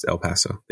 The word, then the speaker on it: El Paso: Yeah, it's pronounced El Paso. Thanks. Yeah. – I said El Paso.